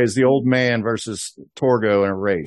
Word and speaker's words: Torgo: And I have to say, Targo. – Your turn to mispronounce. – Is that right? Torgo or Targo? – Torgo. – Torgo. Mm-hmm.